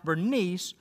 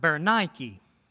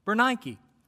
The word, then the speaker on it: Berenike: And it's an interesting name because her Greek name is not pronounced Bernice, but Berenike. Berenike, right? And it comes from that same Greek word that company, Nike, gets their name from today.